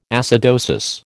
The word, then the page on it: acidosis: American English